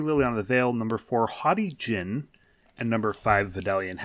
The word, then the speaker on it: Haughty Jinn: It is Haughty, H-A-U-G-H-T-Y, Jinn, D-J-I-N-N.